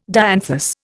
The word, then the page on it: dianthus: Indian